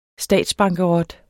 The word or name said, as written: statsbankerot